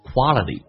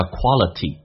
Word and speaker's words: quality: quality 这个单词英式发音大家应该都知道叫 quality，美式发音叫 quality，但你要能听懂，首先那个发啊，最后一个 t 发 d，两个元音中间辅音要被浊化 quality，